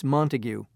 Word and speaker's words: Montague: It's Montague. – Could you spell that, please? – M-O-N-T-A-G-U-E.